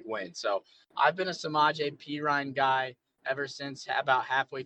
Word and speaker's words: Samaje Perine: This next one, I have Samaje Perine over 2.5 rushing yards at minus 110. – I love it because I picked up Samaje Perine. Also, props to you for getting the name pronunciation right.